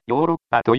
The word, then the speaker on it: ヨーロッパ: こうして、ヨーロッパという発音が定着したのですが、後になって、今度は表記を発音に合わせて、エウロパではなく、ヨーロッパと書くようになりました。